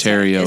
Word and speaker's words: Theriault: That's Terrio. It's spelled like Theriault.